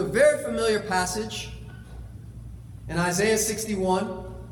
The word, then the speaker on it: Isaiah: The English way that we pronounce it is Isaiah. If you were wondering, everyone else on the planet calls it Isaiah, so just that's where it's coming from.